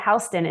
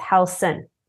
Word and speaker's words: Halson: It's not Halston, it's Halson.